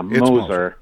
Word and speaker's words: Moser: Is it pronounced Moser, Phil, or is it Mosier? – Yeah, Moser.